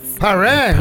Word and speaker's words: Paris: Paré.